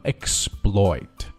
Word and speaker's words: exploit: 它是说到前面的它的影响，所以说读的时候不送气，读的像 b 一样啊，就是读作 exploit。